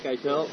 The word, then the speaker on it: Eitel: Jake Eitel. – How do you spell it? – E-I-T-E-L. Jake. – Jake Eitel. E-I-T-E-L.